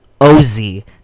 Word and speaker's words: Vozy: Except Vozy is not how you pronounce it. In fact, the V is silent. It's pronounced Ozy.